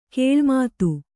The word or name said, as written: ಗಿಳಿಮಾತು